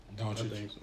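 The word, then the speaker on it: Dončić: Speaking of the Mavericks, Luka Doncic, is that how you say it? Mm-hmm. – Doncic.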